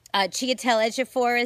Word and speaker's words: Chiwetel Ejiofor: Chiwetel Ejiofor is in it, and I pronounced his name right.